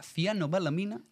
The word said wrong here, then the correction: cianocobalamina: ¿Cianocobalamina? – Bien, ya ha empezado mal.